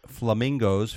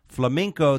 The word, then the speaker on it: flamenco: It's a flamenco dance lessons, but he pronounced it as flamingo, which led the host Pat Sajak to call him a dumbass.